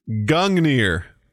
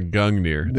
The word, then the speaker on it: Gungnir: It is Gungnir. – That was going to be my second guess, but I thought it would. I couldn't get the pronunciation right. – Yeah, Gungnir.